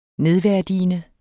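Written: nedværdigende

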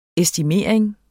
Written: estimering

